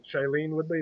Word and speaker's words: Shailene Woodley: And then third right now for me would be Shailene Woodley. Is that how you say her name? – Yeah, I believe so.